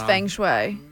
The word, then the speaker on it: feng shui: It's pronounced feng. – Right. – Okay. – Even though it's spelled fang. – Yeah, right. Right. Well, right. – General Gauss is T-S-A-O.